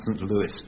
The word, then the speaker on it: St. Louis: I'm told it should be pronounced St. Louis, but never mind.